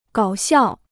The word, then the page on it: 搞笑: gǎo xiào